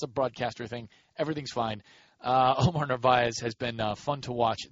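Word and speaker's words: Narváez: You could have a kind of a budding guy in Omar Narvaez, whose name, by the way, is pronounced differently by everybody who gets to pronounce it. Some people put the R after the V where it actually is, kind of like a Brett Favre situation.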